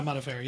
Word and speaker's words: Modafferi: Yeah, Modafferi.